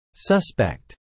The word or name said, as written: suspect